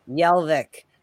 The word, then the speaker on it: Jelvik: Jelvik. – Sorry. Yes. I know you told me earlier. Well, I never know what the J, if it's going to be a Y sound or it's going to be a G sound or it's going to be a J sound.